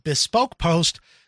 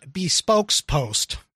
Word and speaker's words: Bespoke Post: This is called Bespoke Post, not Bespokes Post.